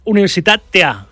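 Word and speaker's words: Universitatea Craiova: Invertir en fútbol con los españoles en Europa a las 7:45 con el Getafe en Rumanía ante el Universitat. No, es que se pronuncia Universitatea, Craiova.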